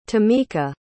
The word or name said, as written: tamika